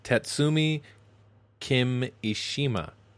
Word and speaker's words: Tatsumi Kimishima: it's Tatsumi Kimishima.